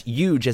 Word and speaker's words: huge: That's huge as in the first syllable of Eugene, but not to be confused with Eugene, who is also Adam Warrock.